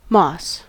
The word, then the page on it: moss: American English